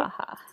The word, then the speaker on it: Musabacha: And it was a great vehicle for the musbacha. Did I say that right? – Musabacha.